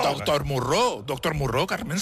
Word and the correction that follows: Moreau: ¡Se pronuncia Moró! doctor Moreau.